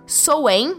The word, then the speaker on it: Samhain: A roda do ano ela rege essa festividade e o Soen, né, que se escreve Samhain, seria a festividade localizada no dia 31 de outubro, que é o Dia das Bruxas.